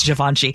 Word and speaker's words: Givenchy: And yeah, I mean, I get when you put it on a jacket, a very expensive Givenchy, you know, whatever it is. – I don't even know how to pronounce that. Well, I have to ask G. – No, it's Givenchy.